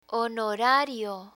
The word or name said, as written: honorario